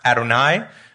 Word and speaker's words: Adonai: So the word for Yahweh, it's often pronounced Adonai, and then we have the Greek word Kyrios for Lord.